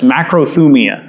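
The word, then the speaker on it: makrothumia (μακροθυμία): The definition of patience, the word patience in Scripture is often a translation of a Greek word pronounced like this, makrothumia.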